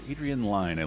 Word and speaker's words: Adrian Lyne: Adrian Lyne, I learned his name is pronounced.